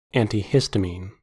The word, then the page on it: antihistamine: American English